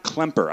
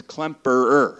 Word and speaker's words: Klemperer: His family fled Germany in 1935 because his father, by the way, it's not Klemper. I always thought it was Werner Klemper, but it's Klemperer.